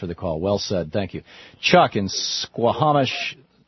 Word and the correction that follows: Suquamish: Please pronounce it, Chuck, Washington. – Suquamish, Washington. – Suquamish, thank you.